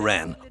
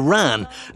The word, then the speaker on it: wren: The tradition of hunting the wren, or the ran, as it is often pronounced in connection with this aspect of our folklore,